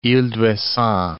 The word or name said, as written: Ouessant, ile d'